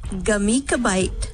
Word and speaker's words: Gemütlichkeit: I'm going to get you to pronounce it properly. Here we go. Gah. – Gah. – Meat. – Meat. – Kla. – Kla. – Kite. – Kite. Glamit. – Not a gla. Gah. Just G-A. Gemütlichkeit.